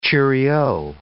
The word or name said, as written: cheerio